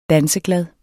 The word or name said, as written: danseglad